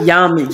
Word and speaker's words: yab-yum: And then I also, in your previous interview, I heard about, I don't even know how to say it, yabdom, the position. – Yab-yum, yes. – I was close. – Yeah, you were very close.